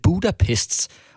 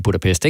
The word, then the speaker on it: Budapest: Men problemet det er, at han udtaler det Budapest. Og så bliver jeg jo på en eller anden måde også nødt til at sige Budapest, i stedet for bare at sige Budapest, ikke?